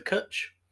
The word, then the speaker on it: Cwtch: The only one, in my personal opinion, I would say stay away from is the Kutch, which is spelt C-W-T-C-H, because it's one of those weird Welsh words that doesn't have vowels.